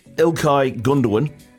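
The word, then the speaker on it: Ilkay Gündoğan: Manchester City captain Ike Gundogan. Hopefully I've said that right. – Ilkay Gundogan. – Gundogan.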